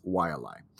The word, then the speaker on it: Wai'alae: Vi, like Count Dracula saying Y, followed by a glottal stop, then Allah, like the French Allah, blended at the end with an unsure eh. Though a frequent pronunciation is Wai'alae.